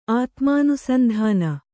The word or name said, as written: ಆತ್ಮಾನುಸಂಧಾನ